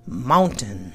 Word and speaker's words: mountain: M-O-U-N-T-A-I-N. It looks like mountain, but it's pronounced two ways. Mountain or mountain. Mountain or mountain. Oop, that was the same. Mountain, or most people go mountain. Like we swallow the T.